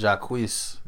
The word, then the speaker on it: Jacquees: Is that how you pronounce his name? – Jacquees. – Yeah, I forgot how to pronounce... I haven't had to say his name out loud in so long, so I forgot how to pronounce it. But yeah, Jacquees, thank you.